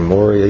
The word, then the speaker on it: Moriah: They go a 3 days journey to Mount Moriah, or Moriah, you can pronounce it either way.